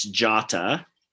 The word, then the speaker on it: Jota: But and just to help a neophyte, it's Jota and Mane. So just helping with the pronunciation there.